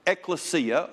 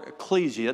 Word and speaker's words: ekklesia: And the word that was used for the word church is the word ekklesia or ecclesia, depending on which pronunciation guide you use.